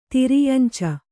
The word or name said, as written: ತಿರ್ಯಂಚ